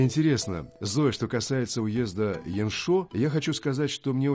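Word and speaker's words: Яншо (阳朔): Недаром в Китае говорят, что горы и вода в уезде Яншо самые красивые в Гуйлине. – Ну, сначала запомните название этого уезда. Яншо. Ян произносится во втором тоне, а шо в четвертом тоне.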